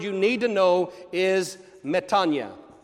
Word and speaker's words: metanoia: Some people have pronounced that metanoia. Do not do that ever again. Don't do it. Don't. No. If somebody says uses metanoia in front of you, tell them, excuse me, no, sorry. It's pronounced metanoia.